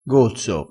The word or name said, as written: gozzo